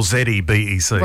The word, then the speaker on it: xebec: Apparently there are two ways to spell this word, either with a Z or an X at the start. – Oh, okay. – So I think it's pronounced XEBEC. – Right. – So XEBEC or ZEBEC.